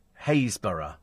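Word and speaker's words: Happisburgh: Happisburg is pronounced Haysborough. Happisburg is pronounced Haysborough, right.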